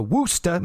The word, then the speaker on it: Worcester: The first order of business was to cancel TV tapings for Worcester, Massachusetts. They pronounce it with a woo and not a war, like where I am from.